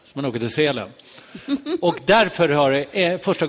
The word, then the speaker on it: Sälen: Sälen, det tänker man inte på. Nu säger jag det som det egentligen ska uttalas. Man tänker inte på att man åker, det har inte med djuret att göra. Utan det betyder ju sel, som betyder fäbod.